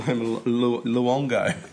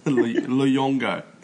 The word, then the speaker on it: Luongo: Massimo Lounga. – Yeah, Massimo Luongo. – Jeez, can you pronounce anything? – I'm hopeless. I'm hopeless. Luongo. Luongo.